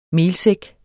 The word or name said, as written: melsæk